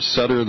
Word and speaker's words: Sadr: It's more Sutter than Sadr.